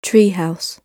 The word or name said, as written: tree house